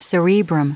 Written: cerebrum

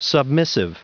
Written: submissive

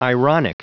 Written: ironic

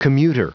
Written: commuter

